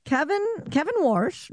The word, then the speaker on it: Warsh: I didn't say enough R there, Warsh. – Yeah, but throw that R in there. – Yeah. Kevin Warsh, new chairman of the Federal Reserve.